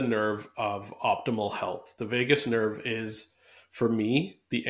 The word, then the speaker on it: vagus: It is vagus, just like Las Vegas, but spelt V-A-G-U-S.